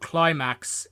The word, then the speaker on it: Klymaxx: Okay, and that's by a band called Climax. It's spelled K L Y M A X X.